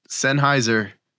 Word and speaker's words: Sennheiser: They're Sennheiser.